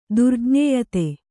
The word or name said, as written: ದುರ್ಜ್ಞೇಯತೆ